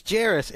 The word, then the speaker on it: Jairus: It's Jarris, and this has come up on the podcast before.